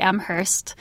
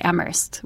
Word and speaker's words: Amherst: If you are going to school in the area or you live there, you don't pronounce the H, but it seems like everybody else does. It looks like Amherst with an H, but all the locals and everyone would say Amherst without the H sound.